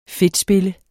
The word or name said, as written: fedtspille